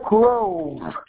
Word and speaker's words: Grove: Gail Grove. – How do you spell that? – G-A-I-L, like the storm. Grove, like Willow Grove Park. – G-R-O-V-E? – Yes, ma'am. – And your first name? – Gail, G-A-I-L, like the storm.